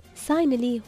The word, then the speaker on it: Signily: ساينلي هو اسم التطبيق، لكن طبعا بالانجليزي الجي عندهم ما تنطق، فنحن عشان شي نقولكم كيف ينكتب SIGNILY.